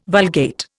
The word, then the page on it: Vulgate: Indian